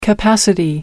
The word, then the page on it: capacity: American English